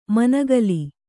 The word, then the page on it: ಮನುಕುಲ: manukula